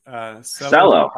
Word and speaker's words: Celo: Celo. Okay. – Excellent. – Celo means purpose in Esperanto. But for pronunciation, I tell people to just imagine that they're using the short form of Marcelo.